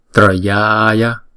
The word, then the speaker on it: Trayaya: Chữ tờ và chữ r rép lại thì chúng ta đọc là tr tr Trayaya.